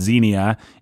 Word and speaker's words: xenia: It's, I believe it's pronounced xenia. It's X-E-N-I-A. – Like xenophobia.